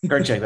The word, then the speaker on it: Kirchick: Kirchick, that's right.